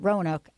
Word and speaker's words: Roanoke: Roanoke. I know I said that right, because you know why? Because my peeps are from Virginia.